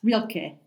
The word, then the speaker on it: Rilke: It's only just come to be released a few weeks ago, which is to the poetry of, and I'm gonna pronounce this wrong, but is it Rainer Maria Rilke? – Yes, Rilke.